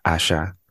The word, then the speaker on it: Asa: It's spelled A S A, but it's pronounced like Ash, like uh, Asha.